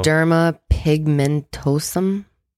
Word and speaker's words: xeroderma pigmentosum: Xeroderma pigmentosum. – Good. Very good. It's exactly how it's pronounced.